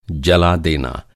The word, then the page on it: जलाना: جلانا